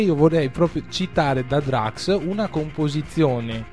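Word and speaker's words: Drukqs: è la pronuncia giusta? – Sì, Drax.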